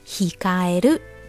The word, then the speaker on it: 控える: hikaeru 汉字呢写作控制的控，再加上假名的 eru，念作 hikaeru。